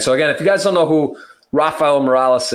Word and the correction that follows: Morais: is, Morais. It's actually pronounced Morais, but I always say that.